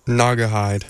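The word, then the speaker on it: Naugahyde: Before the set before that, Primus, Hennepin Crawler off of Green Knot Hen. It says on the CD how to pronounce it. Wait. Okay, hold up. Green Naga Hide. Oh, I was not close.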